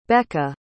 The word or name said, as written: becka